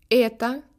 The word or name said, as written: eto